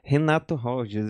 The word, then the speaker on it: Renato Rogers: Renato Rogers, eu acho que é assim que se pronuncia, né? – Oh, Renato é. É, é isso aí.